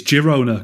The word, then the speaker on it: Girona: Yeah, I think it's Girona because it's Catalan, isn't it? And I think they pronounce the G's properly.